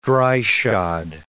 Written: dry-shod

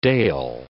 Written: dale

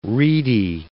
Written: reedy

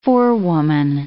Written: forewoman

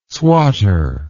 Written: swatter

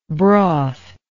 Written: brothy

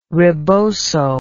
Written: reboso

